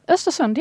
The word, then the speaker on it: Östersund: Right, because, you know, he was that he was the miracle worker at Östersund, or how should I pronounce that? – Östersund, yeah.